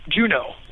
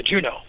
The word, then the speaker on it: Juneau: Juno. – No, it's not like that. It's like J-U-N-E-A-U. – Yeah, Juno.